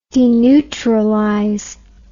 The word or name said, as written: deneutralize